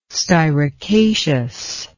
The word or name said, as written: styracaceous